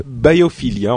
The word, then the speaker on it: Biophilia: Euh, c'est presque ça, parce que euh, Biophilia, en fait, pour prononcer à l'anglaise, c'est le dernier album de Björk.